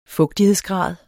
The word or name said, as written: fugtighedsgrad